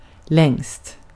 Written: längst

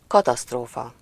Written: ramp

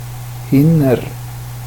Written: hinner

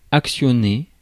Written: actuate

